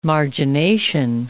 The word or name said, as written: margination